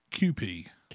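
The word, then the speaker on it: Kewpie: It's pronounced QP. QP. It says on the side.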